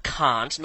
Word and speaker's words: Kant: Yes, that's right, Kant. That's how you pronounce it. Not Kant, not Kant, not Jones, but Kant. It's a German name, and I'm quite happy to sit here in silence until you're mature enough to get over it.